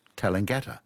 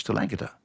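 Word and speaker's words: Tallangatta: Well, when I came back to live in Australia, I thought it was Talangatta in Victoria. And, of course, it's not. It's Talangatta.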